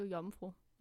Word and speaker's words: Iomfro: Og øh, hendes kunstnernavn, det udtales jo Jomfru, men det staves I-O-M-F-R-O.